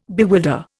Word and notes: bewilder: Indian

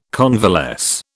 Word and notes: convalesce: British English

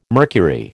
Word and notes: mercury: American English

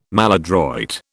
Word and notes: maladroit: British English

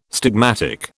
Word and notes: stigmatic: British English

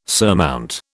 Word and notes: surmount: British English

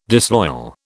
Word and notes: disloyal: British English

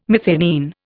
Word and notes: methionine: Indian

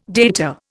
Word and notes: data: Indian

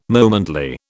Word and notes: momently: British English

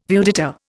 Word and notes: viewdata: Indian

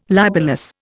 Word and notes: libellous: Indian

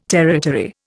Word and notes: territory: Indian

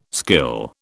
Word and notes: skill: British English

